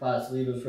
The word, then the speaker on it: pastelitos: I know this personally because I ordered pastelitos from you and they were fucking amazing. So, is that how you say it? – Yeah, yeah. – Did I say it? – You got it.